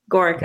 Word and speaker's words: Gaurica: You did, Gaurica.